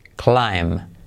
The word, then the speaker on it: climb: Eh, fíjate que tenemos eh, con B, climb, pero no se pronuncia la B climb.